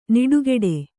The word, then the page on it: ನಿಡುಗೆಡೆ: niḍugeḍe